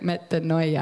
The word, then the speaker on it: metanoia: Okay, so I Googled, how do you say metanoia? And this, like, picture comes up of this little person's mouth, and you hit it, and they pronounce it for you. And she was like, metanoia. And I was like, yeah, so cool. So now I know I'm saying it right.